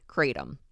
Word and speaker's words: kratom: And am I saying it correctly? Kratom? – Yeah. – Okay. – You are. You got it.